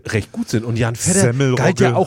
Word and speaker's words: Vetter: Jan Vetter. Nur das Fahre in Urlaub als V-E-T-T-R, also wie der Cousin. – Nicht wie norddeutsch ausgesprochen Fette, sondern nicht wie Helga Feddesen, genau, ne?